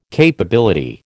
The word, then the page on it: capability: American English